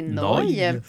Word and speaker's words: Neu: Neu, et, ben, en fait, nœud, je dis Neu parce que je lis Neu, mais en fait, il faut prononcer noy.